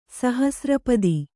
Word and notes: ಸಹಸ್ರಪಾದ: sahasra pāda; Kannada

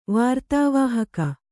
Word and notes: ವಾರ್ತಾವಾಹಕ: Kannada

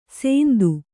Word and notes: ಸೆಂದು: sendu

